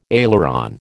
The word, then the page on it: aileron: American English